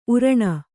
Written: ಉರಣ

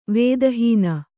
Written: ವೇದಹೀನ

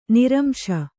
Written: ನಿರಂಶ

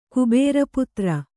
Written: ಕುಬೇರಪುತ್ರ

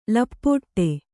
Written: ಲಪಾಟಿ

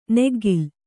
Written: ನಗ್ಗಲು

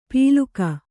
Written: ಪೀಲುಕ

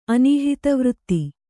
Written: ಅನೀಹಿತವೃತ್ತಿ

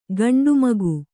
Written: ಖಂಡಮೇಘ

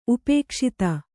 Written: ಉಪೇಕ್ಷಿತ

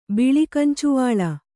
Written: ಬಿಳಿಕಂಚುವಾಳ

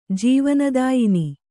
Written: ಜೀವನದಾಯಿನಿ

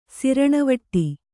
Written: ಸಿರಣವಟ್ಟಿ

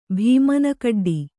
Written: ಭೀಮನಕಡ್ಡಿ

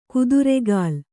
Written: ಕುದುರೆಗಾಲ್

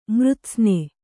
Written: ಮೃತ್ಸ್ನೆ

